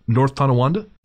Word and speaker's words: North Tonawanda: Is that a particularly known haunted building is it North Tonawanda? – It is North Tonawanda. Good job. You even pronounced it right.